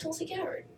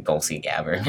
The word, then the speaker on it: Tulsi Gabbard: I said Tulsi Gabbard. Is that all right? – You said Tulsi Gabbard. – Good job.